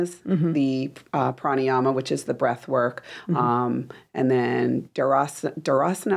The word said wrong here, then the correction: dharana: I think I'm saying that right, I'm sorry, dharana, which is more about meditation, concentration, and of course, samadhi being enlightenment.